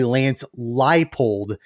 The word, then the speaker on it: Leipold: It's actually Lance Leipold is the pronunciation.